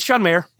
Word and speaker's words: Mayer: It's John Mayer.